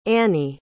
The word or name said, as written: anny